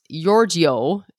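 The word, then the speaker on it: Jordsjø: They're called, well, it's pronounced Jordjo. It's spelled J-O-R-D-S-J-O.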